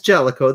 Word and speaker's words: Jaleco: Yeah, it's Jaleco. That's how you pronounce it, right? – That's how Jaleco.